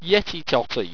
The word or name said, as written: yetitoti